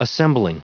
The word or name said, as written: assembling